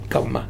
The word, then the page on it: Kama: Futuna; old name